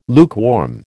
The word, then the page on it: lukewarm: American English